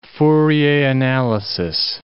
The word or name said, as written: Fourier analysis